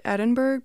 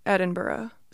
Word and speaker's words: Edinburgh: I think I pronounced it Edinburgh, but that is not right at all. It's Edinburgh. So thank you,